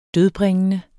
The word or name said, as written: dødbringende